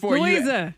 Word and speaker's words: Louisa: It's Louisa. L-O-U-I-S-A.